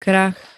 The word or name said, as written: krach